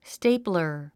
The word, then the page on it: stapler: stéiplər; ステイプラァ